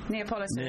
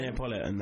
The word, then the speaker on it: Neapolitan: Yeah, how do you pronounce it again? – Neapolitan. – Neapolitan, that's it.